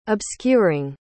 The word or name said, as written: obscuring